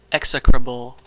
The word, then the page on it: execrable: American English